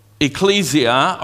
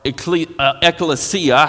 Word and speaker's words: ecclesia: You can call it ecclesia or ecclesia, however, whatever book you looked up the pronunciation in, because there's a couple of different ones of those.